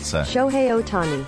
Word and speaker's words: Shohei Ohtani: Shohei Ohtani. – Shohei Ohtani.